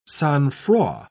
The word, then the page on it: sang-froid: British English